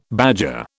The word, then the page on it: badger: British English